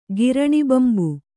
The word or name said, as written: ಗಿರಣಿಬಂಬು